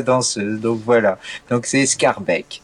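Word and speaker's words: Schaerbeek: Alors, c'est Scarbeck. – Scarbeck. – C'est Scarbeck, on dit.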